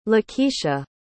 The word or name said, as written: Lakesha